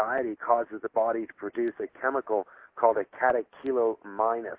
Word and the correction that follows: catecholamine: or a catecholamine. And it's hard to pronounce that one, but catecholamine.